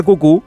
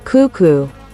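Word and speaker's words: cuckoo: En inglés también, solo que allí la sílaba acentuada es la primera y por eso dicen cucú.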